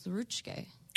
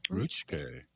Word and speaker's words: Ruucje: And that Ho-Chunk word for pigeon is Ruchke. – Ruchke. Wow. – Yeah, it's spelled R-U-U-C-J-E.